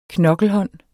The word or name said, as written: knokkelhånd